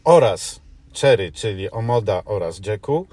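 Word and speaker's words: Jaecoo: a więc Omoda i Jayku, bo to się wymawia Jayku, a nie Jayku.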